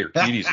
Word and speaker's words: Urquidy: Well, I'm giving you a 10 out of 10 from the Russian judge on the pronunciation there of Jose Urquidy's name.